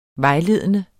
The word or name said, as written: vejledende